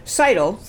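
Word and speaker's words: Scytl: According to a newspaper, the software vendor, how do you say S-C-T-Y-L? – Seidel.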